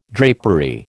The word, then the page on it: drapery: American English